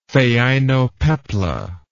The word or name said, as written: phainopepla